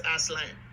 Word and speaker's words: Asselin: Quickly, it kind of flew under the radar for obvious reasons, but the Bruins sign Samuel Asselin. I think that's how you pronounce his name. – I believe the French pronunciation is Asselin.